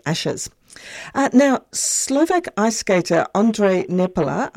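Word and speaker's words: Ondrej Nepela: Yes, so, and very good pronunciation. So it is Ondrej Nepala.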